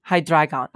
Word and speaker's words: Hydreigon: Hi, like Hydra. Drei, like German for three. – Three? – Yeah, and then Gron, like dragon. Dragon. Hydreigon.